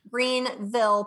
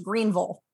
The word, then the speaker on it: Greenville: Greenville, pronounced Greenville. – Greenville. Yeah. All right.